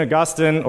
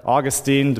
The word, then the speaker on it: Augustine: St. Augustine, or Augustine, depending how you say it, said that our hearts are restless, O Lord, until they find their rest in you.